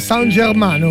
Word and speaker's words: San Germano: Ah, quindi lo pronuncia così, San Germano. – San Germano, come la squadra.